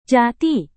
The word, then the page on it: jati: Indonesian